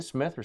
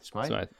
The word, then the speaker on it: Smythe: How do you say it? Smith or Smythe? – Smythe.